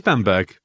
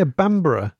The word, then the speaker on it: Bamburgh: It is Bamberg. B-A-M-B-U-R-G-H, as I remember it. – Yeah, Bambara. It's not like Edinburgh.